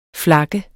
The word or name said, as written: flakken